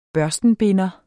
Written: børstenbinder

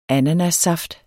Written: ananassaft

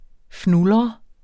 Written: fnulre

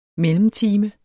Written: mellemtime

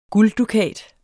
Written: gulddukat

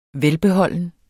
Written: VELBEHOLDEN

